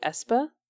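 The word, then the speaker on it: aespa: It rhymes with Vespa, so it's like Espa? – Espa?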